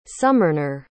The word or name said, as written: sumernor